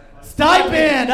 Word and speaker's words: stipend: Am I pronouncing that right? I've only read that word before. – Stippend. – Stippend. – Who can say Stippend? Say it out loud if you know it. Stipend. Oh, right.